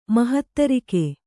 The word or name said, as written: ಮಹಾತಾರಕ